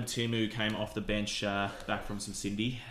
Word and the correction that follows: syndesmosis: From some Cindy? – How do you say? – How do you say it? – Sprained ankle, basically. – We'll no, it's not a sprain. It's a high ankle sprain. – High ankle sprain. – Syndesmosis. – Syndesmosis? – Yeah.